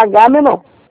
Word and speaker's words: Agamenon: O G é mudo? – É o G normal, Agamenon.